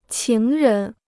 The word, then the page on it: 情人: qíng rén